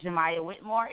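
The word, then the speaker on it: Jaymie Whitmore: So with that being said, you can follow me on Facebook at Jemiah Whitmore. It's spelled J-A-Y-M-I-E. Last name is Whitmore, W-H-I-T-M-O-R-E.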